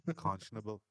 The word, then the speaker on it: unconscionable: and the thought of anything less is unconscious nibblable or something like that. – Unconscionable. – Unconscionable. I can say it properly.